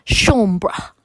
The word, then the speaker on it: Chambre: The ringleader, and this is a French name, John A, but an A with an accent, Chambre, was hanged. You spell that like chamber, but swap the R and the E around.